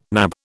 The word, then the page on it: nab: British English